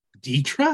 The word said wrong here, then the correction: Dedra: It's a Deidre or Dedra. It's D E D R A is how you spell it.